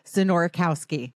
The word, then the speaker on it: Znorkowski: I said it the first time when we practiced, Znorkowski. – I'm so impressed. That is correct.